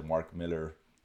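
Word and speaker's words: Mark Millar: The Mark Miller I said it right. – Yeah, you did. First time.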